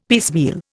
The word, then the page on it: piecemeal: Indian